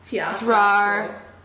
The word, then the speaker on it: Fjordur: The J is like a Y. Fjordur. – Close. – Fjordur.